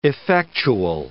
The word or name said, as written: effectual